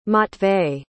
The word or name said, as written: matvey